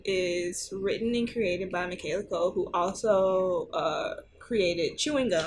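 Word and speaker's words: Michaela Coel: Oh, that's how you say her name? Yes. I thought it was always Michaela. – Nah, her name is Michaela Cole.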